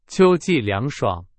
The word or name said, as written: 秋季